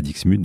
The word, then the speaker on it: Dixmude: qui me confirme donc qu'on prononce bien le X à Dixmude.